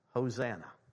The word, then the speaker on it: Hosanna: So if you look in the Hebrew at, save now, I beseech thee, it's pronounced in Hebrew, Hosanna.